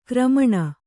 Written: ಕಾರ್ಮಣ

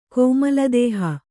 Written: ಕೋಮಲದೇಹ

